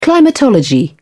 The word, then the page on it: climatology: British English